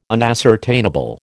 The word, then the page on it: unascertainable: American English